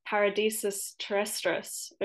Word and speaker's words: Paradisus Terrestris: and you may need to correct my Latin here, Paradisus Terrestris of 1989. Did I pronounce that correctly? – Yes, it sounds interesting.